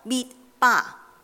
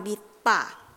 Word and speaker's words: 灭巴: 经文提到摩西喺野外灭巴，千祈唔好以为呢个系英文中途酒吧，呢个唔系英文嚟噶，呢个系希伯来文嘅发音，系旷野嘅发音灭巴。